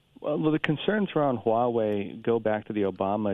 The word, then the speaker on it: Huawei: And is it the company, I don't know how to pronounce it. – Huawei.